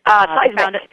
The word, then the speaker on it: SeisMK: She'll correct me later, but it's called SACE, MK. – Seismic. – How is it? – Seismic, but you're very close. We'll take it anyway. – I get it. Morris King, Seismic. Okay, good. It's spelled S-E-I-S-M-K, and it's basically their digital and social media division, which I want to hear about.